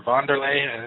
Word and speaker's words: Wanderlei: Wanderlei Silva, or Wanderlei, as it's supposed to be pronounced.